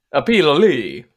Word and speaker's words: Pilloli: P-I-L-L-O-L-I. – Pololi. – Pololi. Yeah, I'm having this hard time today. – Pololi. That's how you can say it.